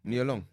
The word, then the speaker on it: Nia Long: In that What's her name, man? Because I always say it wrong. Nia, is it Nia Long? – Nia Long.